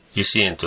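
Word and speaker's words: 由志園: で、僕たちはちょっとあの、おじさんルートと言いますかね、えー、年寄りルートと言いますか、大根島の油脂園というところに行きました。自由の優に志の縁と書きまして、油脂園と読むんですけども、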